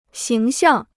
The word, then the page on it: 形象: xíng xiàng